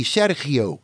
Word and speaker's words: Sergio: The correct way to say his name is not, like I said, Sergio, but closer to what you said, Zoc. You soften the G, Sergio. – Okay. – But certainly not Sergio, under any circumstances. Sergio is how they say it.